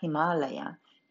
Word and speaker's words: Ιμάλαια: εκεί που έμενε ο Σιβα, στα Ιμαλάια, που προφέρεται στα σανσκριτικά Ιμάλαια. Ιμάλαια σημαίνει ο τόπος του χιονιού.